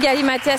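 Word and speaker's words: galimatias: Un galimatia, c'est un discours confus qui semble dire quelque chose mais ne signifie rien. Galimatia, on ne prononce pas le S, donc ça vous l'avez compris.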